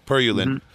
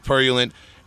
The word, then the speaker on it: Purulent: It's a word in English that is pronounced Purulent. – Yes, Purulent. Mm-hmm. The band Purulent.